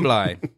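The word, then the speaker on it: Balie: I've got one from Tom Bally. – Tom Bly. – I'll get that eventually. Tom Bly.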